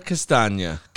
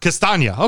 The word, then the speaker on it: Castagna: Can I give you a little help on the pronunciation for that gentleman? – Sure. – Lasagna Castagna. – Castagna. Okay, very good.